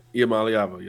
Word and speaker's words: Iamaleava: Yeah, absolutely, and Nico Iamaleava, I think is how you pronounce it. I, I, heard it in the Polynesian Bowl and thought I got the pronunciation right, and I'd all forgotten. I think it's Iamaleava, right? – Yep, Iamaleava, yes, sir.